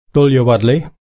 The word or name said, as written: tulya-wodli